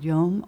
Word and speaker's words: yom: Young Earth creationists would also hold that the Hebrew word yom or yom, can help us with that pronunciation.